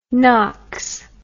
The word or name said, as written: nox